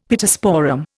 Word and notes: Pittosporum: Indian

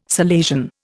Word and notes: Salesian: Indian

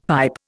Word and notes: pipe: Indian

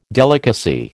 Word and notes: delicacy: American English

